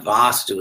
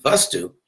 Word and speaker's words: vastu: So, but vastu, the word vastu itself, not vastu with a long e, but vastu means simply an object, a real thing.